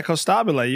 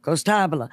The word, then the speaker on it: Costabile: Close, yeah, Costabile. You got it better than most people, especially from the South. – Costabile.